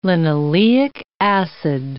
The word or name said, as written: linoleic acid